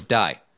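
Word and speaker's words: dai (大): So the kanji for O in Osaka means big, but can also be pronounced as dai, and con refers to convention.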